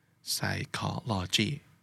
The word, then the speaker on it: psychology: psychology เย่ถูกต้องเอ่อ P เป็น silent P นะครับไม่ออกเสียง P